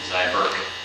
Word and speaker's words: Zyberk: D-U-A-N-Y space P-L-A-T-E-R dash Z-Y-B-E-R-K. – Can you spell the last one again? – Zyberg. – Zyberg. It's Downey, D-U-A-N-Y.